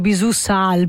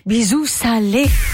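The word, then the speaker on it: Bisou Salé: La prima volta che ho letto questo titolo mi ero detto Bisù Sal, perché sono nei titoli è scritto sempre in grande senza l'accento, dico ma come Bisù Sal? Bisù Salé.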